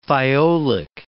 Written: thiolic

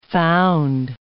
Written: found

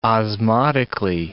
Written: osmotically

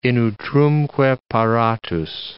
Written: in utrumque paratus

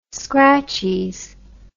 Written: scratchies

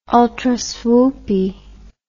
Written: ultraswoopy

